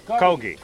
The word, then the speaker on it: Kogi: Is it Kogi or Kogi? – Kogi. – Kogi. – Kogi is beef. – It's beef, okay. I just want to know how to pronounce it.